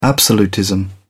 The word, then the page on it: absolutism: British English